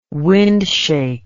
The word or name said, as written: windshake